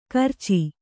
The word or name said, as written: ಗೋರೋಚ